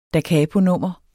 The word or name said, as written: dacaponummer